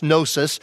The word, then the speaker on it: gnosis: And Gnosticism comes from the Greek word gnosis, G-N-O-S-I-S, gnosis, the G is silent, gnosis, which means knowledge.